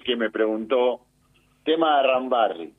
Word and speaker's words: Arambarri: Con una R, Arambarri. – Arambarri, como le gusta a usted. – No, es como se dice. – No estamos hablando de... – Es un apellido, tengo que ir pronunciarlo bien.